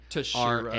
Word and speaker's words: Teixeira: It's Teixeira, and it's Mark Teixeira.